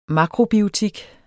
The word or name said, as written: makrobiotik